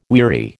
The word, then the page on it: weary: American English